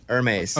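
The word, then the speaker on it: Hermès: The H is silent. – Hermes.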